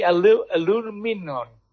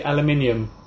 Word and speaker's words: aluminium: It's the aluminum. How do you say that? – In England, we say aluminium.